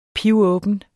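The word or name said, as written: PIVÅBEN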